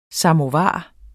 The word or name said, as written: samovar